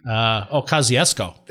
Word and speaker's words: Kosciuszko: Kozyushku, I think I'm pronouncing it. Where did they even get that name? – It's a Polish general thing. – Kosciuszko. – There you go.